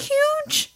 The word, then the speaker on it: huge: Because I remember the first time I noticed, I was like, do you not typically pronounce the H in huge? – Say it again.